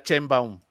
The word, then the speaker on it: Sheinbaum: El mismo Mario Delgado no le sabe decir Sheinbaum. Lo de Sheinbaum, así.